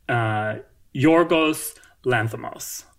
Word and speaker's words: Yorgos Lanthimos: So then the fourth one, I don't know why I butcher this because it's pretty easy, is the director of The Favorite, Yorgos Lanthimos, which is easy.